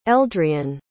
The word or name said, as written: eldrian